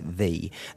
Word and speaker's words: ye: Yeah, yeah, with the O-L-D-E spelling, yeah. – Well, the Y-E, everyone pronounces it ye, but that Y is actually a thing called a thorn. It's an old English letter, which was a combination of a T and an H, and so you do still pronounce it the. That Y in that context amounts to a T-H.